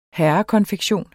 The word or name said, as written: herrekonfektion